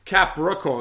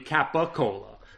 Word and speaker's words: capicola: First off, if you were saying it phonetically, it's not Capricola. It would be Capacola. There's no R there.